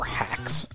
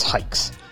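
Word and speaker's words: Haix: Not hikes or hacks or hi-acks. It's hikes.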